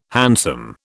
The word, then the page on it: hansom: British English